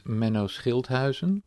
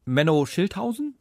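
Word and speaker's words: Menno Schilthuizen: My name is in Dutch would be pronounced as Menno Schildhuizen. – Menno Schildhuizen? – Yeah, that's close enough.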